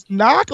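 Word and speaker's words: Nauck: I didn't know that's how you say his last name is Knock. Like, it's spelled crazy. I didn't know it was Knock. – No, it's N-A-U-C-K.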